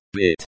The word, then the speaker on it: быть: Быть, быть, to be, pronounced bit.